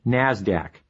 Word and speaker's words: NASDAQ: Today's big two stock markets are the New York Stock Exchange, NYSE, and the NASDAQ, which is pronounced NASDAQ.